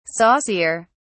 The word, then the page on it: saucier: British English